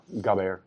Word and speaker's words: gabar: how do you say that, gabar? – Yeah, gaber.